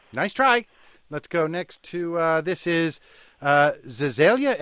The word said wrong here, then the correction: Visalia: Am I pronouncing that correctly? – It's Visalia. – Oh, Visalia in New York.